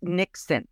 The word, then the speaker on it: niksen: And it's a word, it's pronounced Niksen, and it's the art of doing nothing.